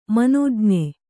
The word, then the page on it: ಮನೋಜ್ಞ: manōjña